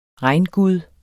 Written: regngud

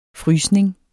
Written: frysning

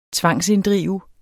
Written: tvangsinddrive

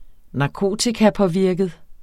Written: narkotikapåvirket

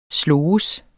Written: SLOGES

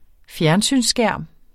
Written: fjernsynsskærm

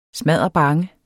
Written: smadderbange

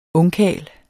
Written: ungkarl

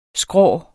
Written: skrå

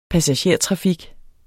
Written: passagertrafik

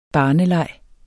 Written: barneleg

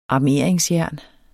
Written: armeringsjern

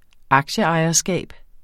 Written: aktieejerskab

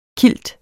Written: KILTENE

